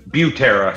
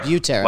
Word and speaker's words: Butera: Is it Sam Butera? – Butera. – Butera. – Like butane. – Okay. Butera. Yeah, butane. Butera. – Yeah.